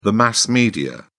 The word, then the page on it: mass media: British English